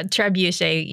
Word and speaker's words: trebuchet: Is that how you pronounce it, by the way, trebuchet? – Well, trebuchet usually. – Trebu- but... trebuchet.